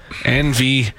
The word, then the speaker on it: NVIDIA: NVIDIA.